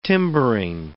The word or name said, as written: timbering